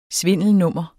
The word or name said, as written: svindelnummer